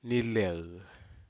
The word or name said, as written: nilel